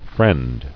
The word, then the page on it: Friendless: friend